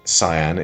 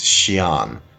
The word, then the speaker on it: Xi'an: The Sui capital was near modern Xi'an, although Mulan is set in northern China and, hang on, it's not Xi'an, it's Xi'an, as in the Terracotta Warriors.